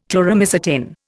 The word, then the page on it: Chloromycetin: Indian